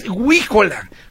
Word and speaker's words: huíjola: Sí, es huíjola, con acento en la I, era un silbato tradicional de las posadas, efectivamente, dice Jesús, buen día.